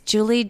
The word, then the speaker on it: Juli: It's J-U-L-I. There's no E on the end of it. So it's Julie, J-U-L-I, Blood on Amazon, Celestial Love.